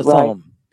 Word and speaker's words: Thummim: And also, what is the Urim and the Thummim? I think I'm pronouncing it right. – The Urim and the Thummim.